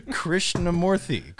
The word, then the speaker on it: Krishnamoorthy: Krishnamoorthy.